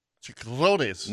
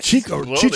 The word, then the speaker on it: chicharrones: The difference between pork rinds and Chris, how do you say that? – Chicharrones. – No. – No. – Chicharrones. – Chicharrones. And who is that called in? – Ed.